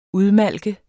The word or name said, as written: udmalke